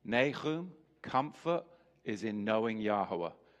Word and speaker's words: Nahum: Nahum, the prophet Nahum.